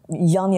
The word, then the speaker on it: Jan: Yan ya da Jan diye telaffuz edelim bunu. Ben Yan diyorum ama Jan diye yazılıyor.